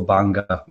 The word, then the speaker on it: Banga: So I'm very happy with Banga, although certain magistrates like to get it right, like yourself, and go for Banga, which is a proper pronunciation.